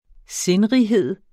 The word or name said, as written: sindrighed